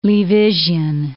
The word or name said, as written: Leavisian